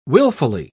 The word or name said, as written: willfully